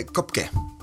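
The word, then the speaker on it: Koepcke: Alltså, jag måste ta elefanten ur rummet och säga att idag så heter ju hon inte, hon heter ju, hette ju Juliane Köpke, eller Kopke, hur man nu uttalar det. K-O-E-P-C-K-E, e. tyskt påbrå. Men Werner Herzog, om du vet vem han är. – Mm. nu kommer de här tyska uttalen igen. Ja, precis. Werner Herzog, som är filmregissör. Han, han säger Juliane Kopke. Kopke? Okej. Okay.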